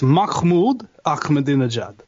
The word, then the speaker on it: Mahmoud Ahmadinejad: I'm told it's Mahmoud Ahmadinejad. – Mahmoud. Right. – Yeah. Like, you know how in Yiddish, you know, like Jewish and stuff, there's a vowel sound called. You know, like you're about to spit on somebody. – Right. – You know, Mahmoud Ahmadinejad. And that's how it's pronounced.